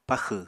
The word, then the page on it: paxw: Apatani